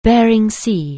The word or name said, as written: Bering Sea